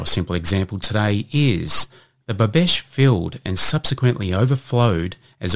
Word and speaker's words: bobeche: And this week's Word of the Week is Bobesh, and that is spelt B-O-B-E-C-H-E.